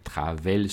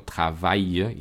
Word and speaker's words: travail: Por ejemplo, las terminaciones a y l, como travail, détail, no se pronuncia e, travel, sino travail y détail.